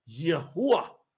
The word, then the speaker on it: Yahuwah: It's Yud, hey, uah, hey. So, in Hebrew, that is pronounced, when you say it in the English, Yahuwah, Yahuwah.